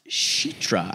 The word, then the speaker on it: Citra: The brother is Sam and Citra. Someone said it's pronounced Sheetra. I think she said it was like Sheetra.